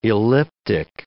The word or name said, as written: elliptic